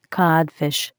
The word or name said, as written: Codfish